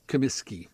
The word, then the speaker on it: Comiskey: Do we go Kaminsky or Comiskey? I always butcher it. – Comiskey. – Okay, no, so Comiskey.